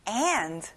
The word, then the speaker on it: and: 무엇무엇와 과 또는 그리고 and 끝에 d 발음까지 들려야 돼요. and 잘했어요.